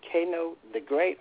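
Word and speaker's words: Cano the Great: Is it Kano the Great or Kano Great? – Kano the Great. – Kano the Great. – C-A-N-O, but it's spelled like it sounded like with the K, so I say Kano.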